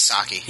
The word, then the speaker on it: Saki: Sakai, or... How do you say it? Is it Saki? – Yeah, it was Saki.